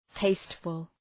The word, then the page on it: tasteful: ‘teıstfəl